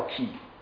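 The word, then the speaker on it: key (Χ): 这个 X 其实不是英语字母里面的 X，它是以前希腊语里面的 X，这个发音叫 key，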